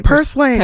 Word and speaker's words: purslane: Purslane, am I saying it right? – Purslane, yeah.